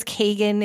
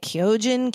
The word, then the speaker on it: Keoghan: And Barry Keoghan, K- K- I thought it was Kagan. It's they kept pronouncing it Keoghan, Keoghan. I don't know.